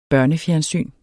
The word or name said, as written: børnefjernsyn